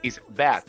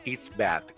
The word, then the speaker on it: it's bad: Atención, la pronunciación no es con una T. Yo aquí no estoy diciendo it's bad. No. Estoy diciendo con una D, it's bad.